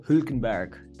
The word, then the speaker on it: Hülkenberg: Nico Hülkenberg. That's my pick of the week. – That's actually very accurate to how you say his name.